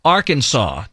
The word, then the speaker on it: Arkansas: A lot of people are still wondering why it's not pronounced like Kansas. So shouldn't it be Arkansas and not Arkansas? – I don't know.